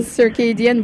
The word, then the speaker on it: Circadian: Circadian, voilà.